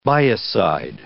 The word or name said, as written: biocide